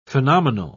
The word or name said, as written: phenomenal